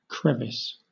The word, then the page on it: crevice: British English